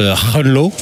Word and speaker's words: Groenlo: Alors, je crois que ça se prononce Groenlo. – Oui.